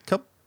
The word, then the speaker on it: Kyub: K-Y-U-B. Cub. – K-Y-U-P. – U-B. That's how it's spelled. K-Y-U-B. Cub. It's our way.